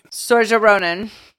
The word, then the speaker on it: Saoirse Ronan: Saoirse Ronan. – Okay. Saoirse Ronan is a talented actress who has already been nominated for 4 Academy Awards.